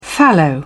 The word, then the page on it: fallow: British English